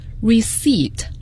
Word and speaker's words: receipt: 这个单词请听众朋友要与 receipt 收据合起来记， receipt 收据 receipt， p 字母是不发音的，发票 invoice，重音在第一个音节，请大家一定要注意 invoice receipt 这两个词都是很有个性的两个词。